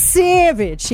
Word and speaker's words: Sauvage: It's S-A-U-V-A-G-E. Everyone pronounces this differently. I trust none of you. Is it Sauvage? Someone's like, no, it's Sauvage. I'm like, Sauvage? Sauvage? Like, I don't... Anyway. – Savage. – Savage. It's Savage.